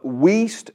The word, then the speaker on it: Wuest: Let me give you the Wuest translation. This is spelled W-U-E-S-T.